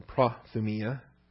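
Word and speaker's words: Prothumia (προθυμία): But look at all the uses here of prothumia. Or prothumia. I like to pronounce my omicrons with a short O sound. Accent is on the iota. Prothumia.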